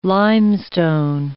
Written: limestone

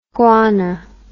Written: guana

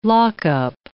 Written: lockup

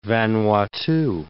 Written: Vanuatu

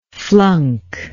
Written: flunker

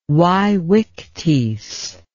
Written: vaevictis

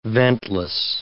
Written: ventless

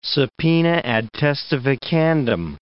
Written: subpoena ad testificandum

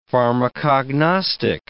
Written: pharmacognostic

